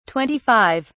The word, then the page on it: 25: British English